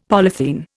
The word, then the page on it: polythene: Indian